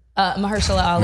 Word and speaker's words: Mahershala Ali: Marshall Ali? – Mahershala Ali. – Mahershala Ali.